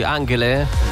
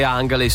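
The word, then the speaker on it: Angèle: Com'è che la pronunci? Angele? – Non lo stavo chiedendo. – Andrei a dire Angele, sì. Featuring Justice, What You Want su Rete 3.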